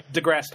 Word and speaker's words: deGrasse: I can never pronounce his name right. – deGrasse Tyson. – Thank you.